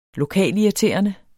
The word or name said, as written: lokalirriterende